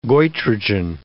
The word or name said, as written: goitrogen